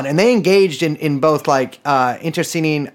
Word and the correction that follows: internecine: I do not think I pronounced that correctly. The kind of warfare where you kill your friends too. Interceding, interceding, internecine, internecine. Damn, first time saying that and not reading.